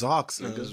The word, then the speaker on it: Zox: O-O-N? – X. – X. – Zox. Yeah, yeah, yeah. That's Zox. – I heard the lady, the CEO pronounce it, so I'm gonna go with Zox.